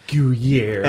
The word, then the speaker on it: Goyer: And I realize that to some extent with a lot of these, with Batman, I can point to Goyer or Goyer or however his name is pronounced. – Goyer.